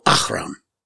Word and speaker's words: Ahroun: I like to go Ahroun. – Yeah, it's guttural. It's guttural.